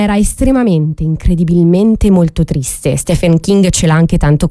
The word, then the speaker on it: Stephen: A proposito di immagini non terribili, un altro grande scrittore più recente, quindi Stephen King, va bene la pronuncia Nati? Stephen. Sì, da Stefano King.